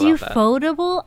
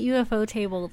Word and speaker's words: Ufotable: Is it pronounced Ufotable? I've always called it UFO table this whole time. – I was going to make that joke, but yeah, it's definitely Ufotable.